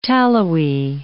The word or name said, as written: tallowy